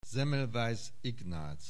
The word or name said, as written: Semmelweis Ignác